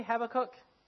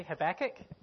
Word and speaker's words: Habakkuk: So, just as a bit of a side note, I say Habakkuk. I asked a few people, they also say Habakkuk. But if you watch the Bible Project, they say Habakkuk. Both pronunciations are fine. I think one might be more American.